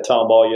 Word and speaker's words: Tomball: And y'all got married down in Tomball, Texas. I probably didn't say that right. – Yeah, Tomball, yes, sir.